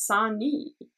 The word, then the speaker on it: Sani: Is it Siam or Sion? – I thought it was Sani. That's what's on the written down on the my drama list. – How is it spelled? Show it's spelled S-A-N-I.